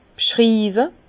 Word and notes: b'schriiwe: Bas-Rhin; Herrlisheim

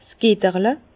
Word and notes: 's Getterle: Bas-Rhin; Herrlisheim

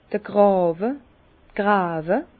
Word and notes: d'r Gràwe: Bas Rhin; Herrlisheim

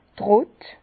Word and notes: d' Trott: Bas-Rhin; Herrlisheim

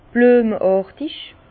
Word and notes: blüemeàrtig: Bas Rhin; Herrlisheim

